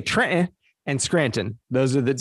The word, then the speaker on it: Trenton: And in New Jersey, how would you pronounce the name of that city? – Trenton. – That's how we say it at home. Trenton. – Trenton. Yeah, we don't say the T, right?